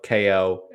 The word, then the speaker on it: KO: So, KO the Kangaroo puts you in the role of KO, and yes, it is pronounced KO, not Cow the Kangaroo.